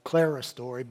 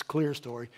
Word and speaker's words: clerestory: And it's, some people have pronounced it clerestory, but it's clear story.